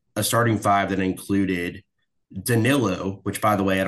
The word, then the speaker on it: Danilo: Everybody says Donilo. Like, where are you looking at that name and thinking that Donilo is how you pronounce this man's name?